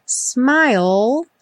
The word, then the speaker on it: smile: Smile, sem pronunciar o E final. Essa palavra vai aparecer muitas vezes. Lembra que a gente termina o som dela com o L lá no céu da boca.